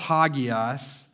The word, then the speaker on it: hagios: It's pronounced hagios.